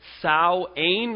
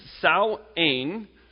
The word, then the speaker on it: Samhain: It began around 3,000 years ago with a Celtic religious festival called Samhain or Samhain. It's pronounced those two ways. Now, it's spelt Samhain, but it's called Samhain or Samhain.